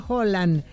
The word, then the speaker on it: Haaland: Ya que hablan de pronunciación, Haaland se pronuncia Holland. Pero ya todos le dicen Haaland, ya fuera de Escandinavia.